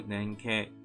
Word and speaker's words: 連携: 好，这边解释阿托巴拉伊是 kino den shimane des，クレジカードを連携されたタイプが対応的で、ポストペイカード電子マネーのように。チャージの残高が足りなくて支払えないといったことがなくなります。这边解释一下，那个連携汉字写作联系，读作連携，那就是合作。